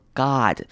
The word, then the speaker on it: god: 这边 god 要特别留意，要念成短母音 O 的声音，反念作 god，不用念成 oh my god，这样就不对了，很多人会念成这样子，要念成 odd 的声音 oh my god。